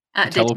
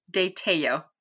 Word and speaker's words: Tello: Tello. De-tello. – What was that? – Tello. The L is like Y.